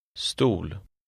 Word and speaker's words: stol: The word in number 3 contains the unaccented Swedish O, which is pronounced somewhat like the double O in tool. Try to read number 3. – Stol.